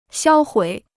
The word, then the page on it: 销毁: xiāo huǐ